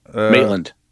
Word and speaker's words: Maitland: How do you say their last names? – Maitland. – Maitlands. Yeah, that's what it was.